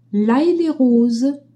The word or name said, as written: Hay-Les-Roses